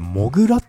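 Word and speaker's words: 土竜: 土の竜って書いてモグラって読むんですね。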